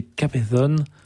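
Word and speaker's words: Cabezón: Alors, un mot sur le Magnificat qu'on va écouter dans un instant d'Antonio de Capezon.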